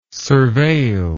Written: surveil

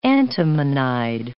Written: antimonide

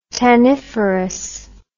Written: tanniferous

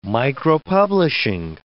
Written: micropublishing

